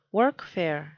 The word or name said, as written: workfare